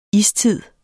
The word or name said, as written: istid